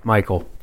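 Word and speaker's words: Mychal: Michael. Right. It's actually Michael.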